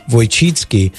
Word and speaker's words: Wojcicki: It's pronounced Wojcicki.